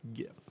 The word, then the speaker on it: GIF: Because it's a graphic. The first word in the abbreviation is graphic, so that's a hard G. So you should say GIF.